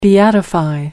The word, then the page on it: beatify: American English